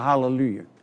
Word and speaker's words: Hallelujah: I heard a half of a hallelujah. Just a holler. I heard a holler. Ha, ha, ha, ha. It's a few syllables. Hallelujah. Not ha.